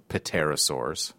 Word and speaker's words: pterosaur: Isn't the P not technically pronounced? – That's, like, yes, pterosaur? True. – Okay, you're just doing it to be funny. Okay, so people aren't confused. – Well, and the listener will now know that it starts with a silent P instead of a T.